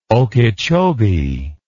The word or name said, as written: okeechobee